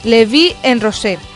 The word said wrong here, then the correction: La Vie en Rose: La Vie en Rose. – Eso es.